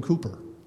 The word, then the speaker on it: Cowper: His name is William Cooper. It's spelled C-O-W-P-E-R.